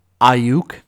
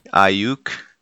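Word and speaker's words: Aiyuk: Awik. How do you say that? – Ayuk. – Ayuk. – Yeah.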